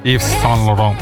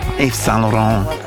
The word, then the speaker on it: Yves Saint Laurent: Ahora, Yves Saint Laurent, o no sé cómo se dice, Yves Saint Laurent. – Yves Saint Laurent. – Yves Saint Laurent.